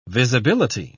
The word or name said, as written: visibility